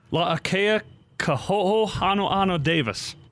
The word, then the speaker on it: La'akea Koho'o Hanohano Davis: La'akea Koho'o Hanohano Davis. – Now, this is what I was told when trying to get the Polynesian names correct. You're supposed to pronounce every vowel. Is that right? – That's correct.